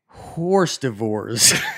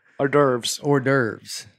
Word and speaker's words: hors d'oeuvres: The worst word I butchered while reading out loud was horse divorce. – Hors d'oeuvres. – Yeah. I pronounce it as ours devours.